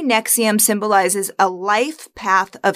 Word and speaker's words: NXIVM: Well, first of all, it's pronounced Nexium, like the heartburn medication.